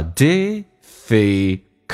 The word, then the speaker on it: difficult: 这个单词有点难发，尤其是那个 l 这个发音叫 difficult。很多人发成 difficult，口是错的，cull。 l 这个发音是舌尖抬起来，靠向你的上齿龈、上牙齿后背一点点，叫 oh difficult these days。